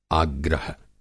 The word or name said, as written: आग्रह